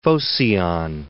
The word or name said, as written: Phocion